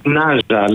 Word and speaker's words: Νάζα: Πώς προφέρεται το ντοκιμαντέρ? – Νάζα λέγεται. – Νάζα.